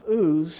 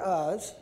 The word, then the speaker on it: Uz: So in the land of Uz, how do you say it? Or Uz. Either one, lived a man whose name was Job.